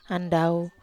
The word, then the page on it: andau: Wamesa